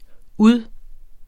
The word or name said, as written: ud